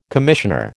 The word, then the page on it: commissioner: American English